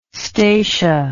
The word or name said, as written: stacia